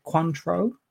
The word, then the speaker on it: Cointreau: So that is vodka Cointreau. I believe is the pronunciation, as we went through last week.